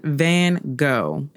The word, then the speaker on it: van Gogh: So, without further ado, this week we will be covering the striking, the stunning, the standout, Vincent van Gogh, which is the Dutch pronunciation, which I probably butchered, but Vincent van Gogh is how it's most commonly pronounced in the U.S.